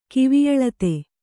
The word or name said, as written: ಕಿವಿಯಳತೆ